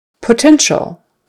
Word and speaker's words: potential: Potential, potential, that ends in a T-I-A-L, and that T-I is a sh, S-H sound. Kind of like the T-I-O end has an S-H sound, the T-I-A-L has an S-H sound instead of a T.